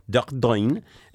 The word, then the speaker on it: Dordogne: Dordogne, and Garonne rivers meet.